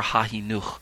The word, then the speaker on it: Sefer HaChinuch: The next term on my list is Sefer HaChinuch, And sometimes you'll see it spelled with a C-H-I, and other times you'll see it just spelled with a H-A, say for Hahinuch.